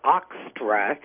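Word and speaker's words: Eostre: Well, the Astera, or actually, I think is German, is literally pronounced kind of Ostra, and it's E-O-S-T-R-E.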